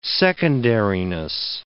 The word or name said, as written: secondariness